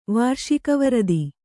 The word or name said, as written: ವಾರ್ಷಿಕವರದಿ